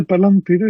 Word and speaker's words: Palantir: se pronuncia Palantir, eso.